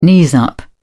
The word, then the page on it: knees up: British English